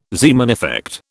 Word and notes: Zeeman effect: British English

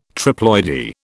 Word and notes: triploidy: British English

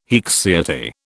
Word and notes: haecceity: British English

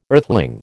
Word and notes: earthling: American English

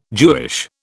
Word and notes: Jewish: British English

